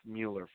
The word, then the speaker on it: Mueller: And in the world of politics right now, folks, if a Mueller or Mueller, it's Mueller, folks, do it correctly, say it correctly, pronounce it correctly.